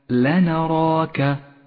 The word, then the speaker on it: لنراك: لنراك، ولاحظ أن حرفي اللام والنون مرققان والراء مفخمة والألف التي بعدها مفخمة، ولاحظ هذه الياء الرمادية إشارة إلى عدم نطقها. اقرأ الكلمة هكذا لنراك.